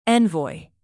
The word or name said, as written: envoy